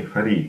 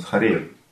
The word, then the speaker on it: Харе: Поэтому у нас Юга Мантра, у нас не Хари Кришна, у нас Харе Кришна, да? То есть имеется в виду Хара, Радха Кришна. А когда Радхика в разлуке с Кришной, и ей надо произносить имена Кришны, тогда Манджари произносит Хари. То есть Харе как Хари.